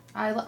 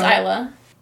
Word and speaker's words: Isla: Amy Adams and Elsa Fisher. – Isla. – I don't even know how to pronounce things. – See, here's the thing. – I've been saying this wrong all my life then. Every time I've talked about this woman, I'm sorry. – I'm pretty sure it's Isla. I think.